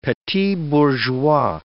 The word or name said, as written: petit bourgeois